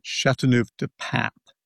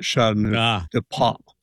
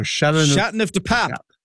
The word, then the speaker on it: Châteauneuf-du-Pape: And let me correct it by saying Châteauneuf-du-Pape, not Châteauneuf-du-Pop. So Châteauneuf-du-Pape.